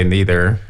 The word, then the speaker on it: neither: Americans usually say neither. – Right. – And、 perhaps British or some very educated Americans will use neither, the more formal pronunciation.